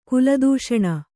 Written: ಕುಲದೂಷಣ

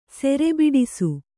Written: ಸೆರೆಬಿಡಿಸು

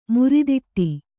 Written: ಮುರಿದಿಟ್ಟಿ

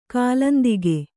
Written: ಕಾಲಾಂತಕ

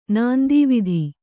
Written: ನಾಂದೀವಿಧಿ

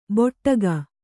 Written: ಬೊಟ್ಟಗ

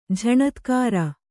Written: ಝಣತ್ಕಾರ

